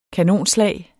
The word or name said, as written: kanonslag